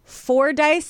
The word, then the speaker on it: Fordyce: just be aware of is Fordyce spots. Did I say it correctly? You did.